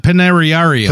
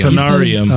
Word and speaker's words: Panarium: He said Panariarium. – Panarium. – Oh, okay. – He added a few extra syllables.